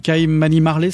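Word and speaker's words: Kymani Marley: Nous aurions pu citer aussi Twinkle Brothers, Kaimani Marley, c'est comme ça qu'on prononce? – Ouais.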